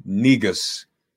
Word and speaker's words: negus: He is a self-described, world-renowned white people-ologist, getter and doer of it, never reneged, never will, and the last real negus alive. And I guess I'm pronouncing that right. I mean, that's how it's yes. – The pronunciation yes. In the dictionary.